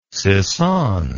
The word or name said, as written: sissonne